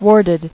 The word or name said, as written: Warded